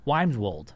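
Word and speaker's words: Wymeswold: Badgers of Wimeswold. – Wimeswold. – Wimeswold. Or Wimeswold. Wimeswold? – It sounds like Wayne's World, but it's not.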